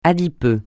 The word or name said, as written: adipeux